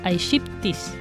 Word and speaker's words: aegypti: que era ocasionada por el famoso mosquito Aedes aegypti. Aegypti, lo dije bien.